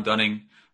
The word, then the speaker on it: Dunning: It's Sam Dunning. My surname is spelled D-U-N-N-I-N-G.